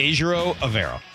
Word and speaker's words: Evero: Evero Evero is how he pronounced it himself.